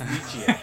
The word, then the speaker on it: Nietzsche: How is it pronounced? – In the show, it's Nietzsche.